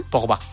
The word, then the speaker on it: Pogba: Pogba, ¿no? – Pogba.